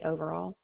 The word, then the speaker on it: Overall: Lori Overall.